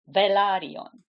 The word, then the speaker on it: Velaryon: So the country, the place is Valyria. The house is Velaryon.